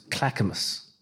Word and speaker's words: Clackamas: I went out to, I'm going to mispronounce it, Clackamas. – You got it.